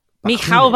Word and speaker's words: Mikhail: No, Mikkel Bakunin, I think is how it's pronounced. – Hang on. Bakunin. Mikhail Bakunin. I don't know. – There we go. No, that's probably accurate.